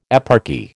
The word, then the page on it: eparchy: American English